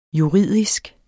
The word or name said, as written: juridisk